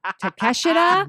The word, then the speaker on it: Takeshita: I gotta ask this question: How are you dealing with Konosuke Takashita, tak- Takeshita.